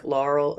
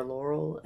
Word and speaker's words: Laureen: I know, it's people always ask me, do you like Laurel or Laurel? And I'm like, I don't care. – Laurel is easier, I guess, for me to pronounce. I always just say Laurel. – I know, and it's like, but everybody in my life has their own way.